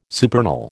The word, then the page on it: supernal: American English